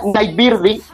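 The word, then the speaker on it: Nightbirde: Se pronuncia Nightbirdie porque tiene una E al final. Es como okay. Blondie.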